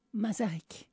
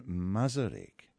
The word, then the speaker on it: Masaryk: You raise one political character in particular, Jan Masaryk. – Jan Masaryk.